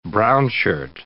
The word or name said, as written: brownshirt